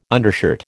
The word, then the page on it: undershirt: American English